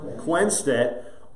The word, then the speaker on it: Quenstedt: Quenstedt.